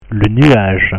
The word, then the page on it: le nuage: French